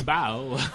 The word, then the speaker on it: Bao: Bao.